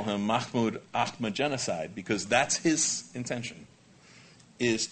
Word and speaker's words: Mahmoud Ahmadinejad: You have a president of Iran, Mahmoud Ahmadinejad, is how you pronounce his name. If you prefer to pronounce his name Mahmoud Ahmadinejad, you can do that too.